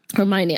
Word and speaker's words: Hermione: Hermon, Hermione eller Hermoine eller vad uttalar hennes namn i Hermione. Harry Potter. Ja. – Hermione, ja.